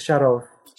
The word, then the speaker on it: Sharov: And it's Vladimir Sharov, right? Not Sharov. – It's Sharov. – Sharov. – Okay. Sharov. The stress is on the second syllable.